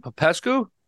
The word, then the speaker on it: Popescu: Popescu? – Popescu, very good, yes.